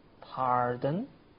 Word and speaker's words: pardon: 注意你的发音，pardon 里边的 r 是长音，它和字母 r 发音是一样的。你再试试看，pardon。